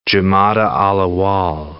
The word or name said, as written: Jumada al-Awwal